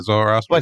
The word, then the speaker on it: Zoroastrian: Yeah, Zoroastrian.